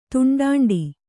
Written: ತುಂಡಾಂಡಿ

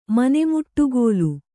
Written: ಮನೆಮುಟ್ಟುಗೋಲು

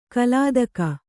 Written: ಕಲಾದಕ

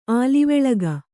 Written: ಆಲಿವೆಳಗ

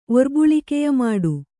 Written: ಒರ್ಬುಳಿಕೆಯಮಾಡು

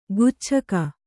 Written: ಗುಚ್ಛಕ

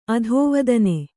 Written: ಅಧಿವೇದನ

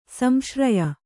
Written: ಸಂಶ್ರಯ

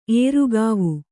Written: ಏರುಗಾವು

